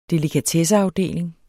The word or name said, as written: delikatesseafdeling